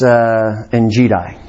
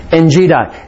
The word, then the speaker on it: Engedi: Again, Alexander Scorby, you might think, doesn't that Engedi? Well, this isn't the Serengeti. This is Engedi. Again, we'll just pronounce it as Alexander Scorby does. Engedi.